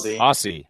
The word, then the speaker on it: Aussie: How do you say Aussie as far as Australian? – Aussie.